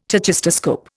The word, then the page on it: tachistoscope: Indian